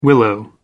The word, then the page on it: willow tree: British English